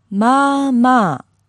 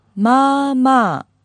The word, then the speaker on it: まあまあ: まあまあ. – Very nice. Yeah, you want to kind of hold it in there. One more time, please. – まあまあ.